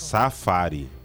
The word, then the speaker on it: Safari: é que aconteceu com nós uma vez, nós tava, fui nos, nos, nos é Zafari, Zafari, que fala, como é que fala? Que fica vendo os leões, sim, que fica vendo os bichos. – Safari.